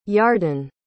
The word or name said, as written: yarden